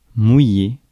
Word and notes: cast: /ˈkæst/

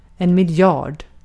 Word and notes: miljard: /mɪlˈjɑːrd/

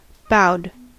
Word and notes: bowed: /ˈboʊd/; American English

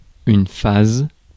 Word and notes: phase: /ˈfeɪz/; [ˈfeɪz]

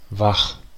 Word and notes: wach: /vax/; [vaχ]

